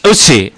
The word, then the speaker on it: Eutsi: Eutsi. – Eutsi. – Y si fuese con Z sería Eutsi.